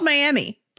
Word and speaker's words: Miami: So this is in Miami, spelled Miami, pronounced Miami, Oklahoma. And there's a couple restaurants that do this.